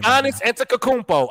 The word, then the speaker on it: Giannis Antetokounmpo: You should probably say that name right. You probably learn how to say that name. – Giannis Antetokounmpo. I said it correctly, bitch.